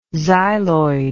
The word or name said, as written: xyloid